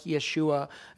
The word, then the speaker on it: Yeshua: So Jesus would have been pronounced something like Yeshua.